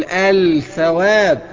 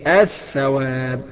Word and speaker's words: الثواب: بعد الطاء هنلاقي الثاء، هنقول الثواب، اوعى تقول الثواب، انما الصحيح فيها ايه؟ الثواب بادغام اللام في الثاء، فيصير بنطق ثاء خالصة بعد الايه؟